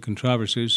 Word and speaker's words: controversies: Ryle writes, that we should notice in this passage what strifes and controversies, or I should say controversies, since that's the British pronunciation, but I'm not going to do that except I just did.